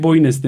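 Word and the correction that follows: Boine: demiştim. Mari Boyna diye telaffuz ediliyormuş.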